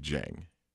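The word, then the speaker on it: Dieng: It's not Gorgie Dang, it's Gorgie Jang.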